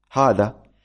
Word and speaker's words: هذا: مثلا هذا، هذا، هذا تكتب بهاء وذال وألف، هاء وذال وألف، هذا، لكنها تلفظ بهاء ثم ألف ثم ذال ثم ألف، تقول هذا، هناك ألفان.